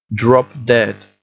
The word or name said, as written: drop dead